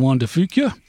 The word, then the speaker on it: Juan de Fuca: May 2nd, exactly 49 years after Vancouver, they anchored in the Strait of Juan de Fuca. think I should be able to pronounce that right. – Sounded close enough.